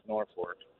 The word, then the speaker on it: Norfolk: Yeah, I lived in Norfolk for 20 years, and it is pronounced Norfolk.